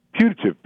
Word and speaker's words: putative: And how did you say that word again? Putative? – Putative, P-U-T-A-T-I-V-E. It's kind of a legal term of art, although it's also used by ordinary humans sometimes.